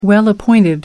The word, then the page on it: well-appointed: American English